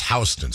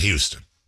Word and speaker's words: Houston: Kind of screwed me up the first time I was in New York, Jason, and they have a street that's spelled the exact same way as the city there, but it's pronounced Houston Street, not Houston.